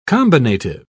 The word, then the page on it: combinative: British English